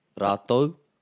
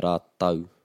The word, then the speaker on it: rātou: So if you're on the east coast of the North Island, you'll hear rātau, rātau, T-A-U. The west coast, you're going to hear rātou, T-O-U.